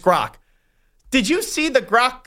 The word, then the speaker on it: Grok: It's the Gork AI. Is that how you say it correctly? Do you have any idea how to pronounce Grok. – That? Grok? – It's like Gronk without the N.